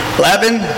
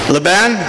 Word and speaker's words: Laban: I think Dave's, it's L-A-B-A-N, David, how do you pronounce it? Laban? Laban? Laban?